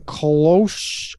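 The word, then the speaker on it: Kölsch: How do you say the word K-O-L-S-C-H? – Kolsch.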